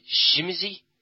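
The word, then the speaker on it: Tzimisce: The two that come out to start off with is the Tsimitze. Now, you I noticed, pronounced it somewhat differently. – I believe it's a Polish word. And I work with some Polish people, and they pronounce it Shimizy.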